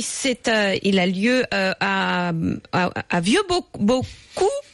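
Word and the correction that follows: Vieux-Boucau-les-Bains: Vieux Boucaux les Bains.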